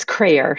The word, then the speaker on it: Crayer: Yeah, it's Crayer, so rhymes with prayer.